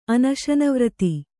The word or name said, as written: ಅನಶನವ್ರತಿ